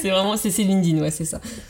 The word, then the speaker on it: LinkedIn: Bon, après, c'est pas grave, en France, on dit beaucoup LinkedIn, et, euh, c'est accepté, hein, on, reconnaît, mais bon, voilà, la vraie prononciation, euh, c'est en fait, c'est un passé simple, en fait, c'est pour ça qu'on zappe le K et le E.